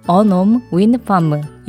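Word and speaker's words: Un homme et une femme: 그래서 어놈 윈드팜 이 정도로 발음한다고 해요.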